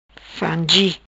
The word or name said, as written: Frangi